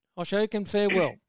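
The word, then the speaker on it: Ashokan Farewell: We are now back with, oh, how do I pronounce this? Oshoken Farewell? Did I say that right? Oshoken? – Yeah. – Oshoken Farewell.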